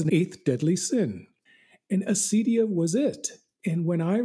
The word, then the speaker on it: acedia: Yes, you were correct in the first instance. At least that's the way I pronounce it, Pandora. Yes, acedia. In the ancient world, it was acadia.